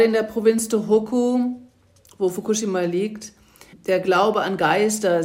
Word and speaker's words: Fukushima: Wir sind ja immer versucht, Fukushima zu sagen, was aber falsch ist, ne? – Ja, also es ist keine eindeutige Betonung auf der zweitletzten Silbe. – Es okay. ist eher so gleichförmig Fukushima.